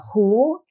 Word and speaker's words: Hu: My name is Minna, and my last name is, you may not know how to pronounce in Chinese, but it's Hu. Sounds like a Chinese, sounds like a Hu in English.